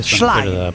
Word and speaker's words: Schlein: Sì, dai, intanto non è difficilissimo, impariamo il nome, Schlein, no, Schlein, cioè non si può sentire, caro ascoltatore, Schlein, non è difficilissimo, anche se sì, non sappiamo il tedesco.